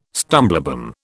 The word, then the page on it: stumblebum: British English